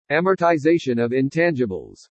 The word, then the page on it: AMORTIZATION OF INTANGIBLES: english